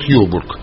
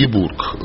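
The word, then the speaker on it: Ёбург: Я не уверен, что все здесь присутствующие знают про город Йобург. Или Ебург, он по-разному произносится.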